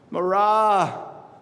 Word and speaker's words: Marah: Marah. The emphasis is on the second syllable.